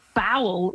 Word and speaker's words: bowel: Bowel rhymes with owl, and that's not really in your kitchen, so.